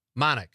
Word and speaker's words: Manick: Am I saying that right? Manick? Manick. I thought so. Rachel Manick. Rhymes with Bionic.